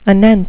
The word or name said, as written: anent